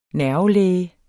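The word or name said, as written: NERVELÆGER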